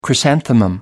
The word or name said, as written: chrysanthemum